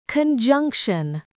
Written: conjunction

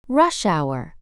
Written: rush hour

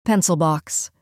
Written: pencil box

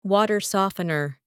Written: water softener

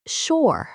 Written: shore

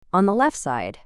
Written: on the left side

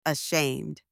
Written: ashamed

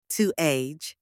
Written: to age

